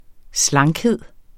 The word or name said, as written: slankhed